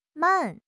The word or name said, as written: man4